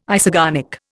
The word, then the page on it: isogonic: Indian